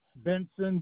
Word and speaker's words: Benson: Eric, E-R-I-C. – E-R-I-C, last name? – Benson, B as in boy, E-N-S-O-N.